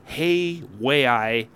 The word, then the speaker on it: Hawaii: It's doing its Hawaii swing. Did I say that right? Hawaii. Hey, way I out there.